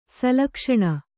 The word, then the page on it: ಶ್ಲಕ್ಷ್ಣ: Kannada